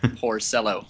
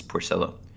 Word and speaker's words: Porcello: Yeah, we Porcello. – Yes, Doug Thorburn clarified for us from the Bill James Handbook, which apparently has a pronunciation guide, that it is Porcello.